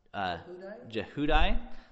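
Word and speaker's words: Jehudi: Verse 14, then all the officials sent, how do you say that one? – Jehudai, the son of Nathaniel, or no, sorry, Nathaniah.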